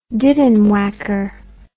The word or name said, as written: diddenwhacker